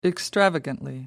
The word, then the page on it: extravagantly: British English